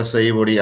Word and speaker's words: Savory: Savory.